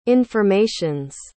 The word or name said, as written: informations